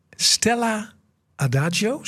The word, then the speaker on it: Stella Adagio: Stella Adagio, zeg ik dat goed? – Ja, dat zeg je goed.